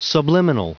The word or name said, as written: subliminal